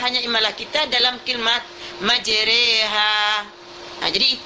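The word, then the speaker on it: majereha (مجراها): majereha, majereha. Itu kita membacanya majereha. Tak boleh kita baca majeroha. Ada yang baca majeroha. Tak boleh kita baca majriha.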